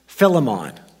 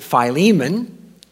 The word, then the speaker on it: Philemon: I've always pronounced it Philemon, and Haddon Robinson said, if you really want to know how it's pronounced in English, it's Philemon. I'm not going to argue with Haddon Robinson, but if he doesn't mind, I'm going to call him Philemon.